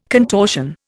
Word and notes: contortion: Indian

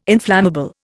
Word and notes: inflammable: Indian

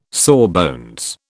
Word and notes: sawbones: British English

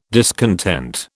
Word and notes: discontent: British English

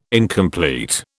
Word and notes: incomplete: British English

